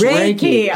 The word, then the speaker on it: Reiki: Is it Reiki or Reiki? – Yeah. How do you pronounce that? – It's Reiki.